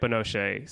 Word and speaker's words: Binoche: Juliette Binoche.